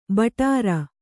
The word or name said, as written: ಭಟಾರ